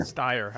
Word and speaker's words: Steyer: So this has been brought to you by Energy by Duracell, Humana, Humana and Tom Stayer. – Tom Stayer. – Steyer, however you say his name.